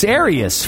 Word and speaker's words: Arius: No, it's Arius. Father of Arianism. Arianism. – Arius. Spell it. – A-R-I-U-S.